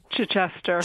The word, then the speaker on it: Chichester: Chichester.